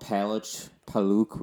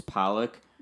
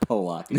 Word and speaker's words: Paluch: Paluch, which is the correct way of pronouncing it in Polish. In English, it's Palak. Mm-hmm. I've had some telemarketers call me like Polak. No, that's not my last name. I'm Polish, ironically, but it's not pronounced Polak. It just looks like it.